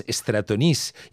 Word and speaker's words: Stratonice: De fet, potser l'hauríem de pronunciar en francès, Stratonis, igual que Medea, que l'hem sentit en italià, però realment, quan es va interpretar a París, es va fer en francès.